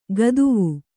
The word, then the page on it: ಗದುವು: gaduvu